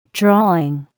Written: drawing

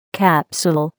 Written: capsule

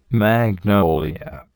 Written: magnolia

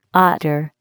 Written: otter

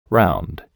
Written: round

